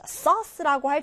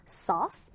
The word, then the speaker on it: sauce: 자, sauce라고 할 때 여기 발음 기호 잘 보시면 이렇게 왼쪽이 뚫려있는 이 O 발음 있죠? 이거는 쌤이 O와 어의 중간 발음이라고 했습니다. sauce.